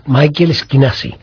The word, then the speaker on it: Michael Schinasi: un profesor norteamericano que yo siempre me confundo, digo Michael Schinasi, pero estoy seguro que me lo pronuncia mucho mejor. A ver, ¿cómo es? – Michael Schinasi. – Exacto.